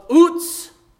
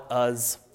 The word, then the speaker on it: Uz: So all we know is that Job is from the land of Uz, is how you're supposed to pronounce that. So thank you, Matt, for pronouncing that correctly. But again, we Americans just love to call it Uz.